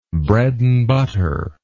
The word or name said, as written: bread and butter